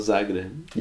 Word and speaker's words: Zagreb: Dinamo Zagreb.